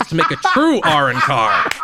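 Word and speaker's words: Arrancar: I don't know how the original dub says it, but in the U.S. dub, it's pronounced Arankar, just so that way we don't get through the whole episode without me doubting.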